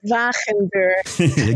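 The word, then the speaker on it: Wagenbuur: I probably mispronounced his name. – Wachenberg. – Exactly.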